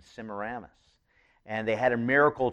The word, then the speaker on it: Semiramis: Semiramis is a difficult word. It's spelled S-E-M-I-R-A-M-I-S. So it's kind of Semiramis is kind of how it's spelled. You